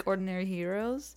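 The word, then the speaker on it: Xdinary Heroes: Yeah, it's still for some reason pronounced like Ordinary Heroes.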